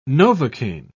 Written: novocaine

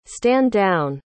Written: stand-down

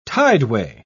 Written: tideway

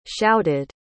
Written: shouted

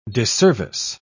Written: disservice

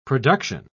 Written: production

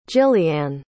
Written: jilliane

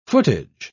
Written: footage

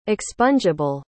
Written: expungeable

